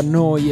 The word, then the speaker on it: Neu: Si scrive Neu, si pronuncia Noi e vuol dire nuova, nuova emittente urbana in diretta da Bologna, 7 giorni su 7, 24 ore su 24.